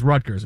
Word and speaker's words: Rutgers: And just so everybody know, it's Rutgers. It's not Rutgers. It's Rutgers. That's how you say it. – Okay? Well, it's the State Rutgers. College of New Jersey. – It's Rutgers. That's how you say it. It's always been said Rutgers. There's no, you know, you have to say R's when you have to, but you don't say all the R's, right?